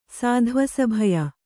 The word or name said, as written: ಸಾಧ್ವಸಭಯ